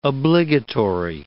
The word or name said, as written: obligatory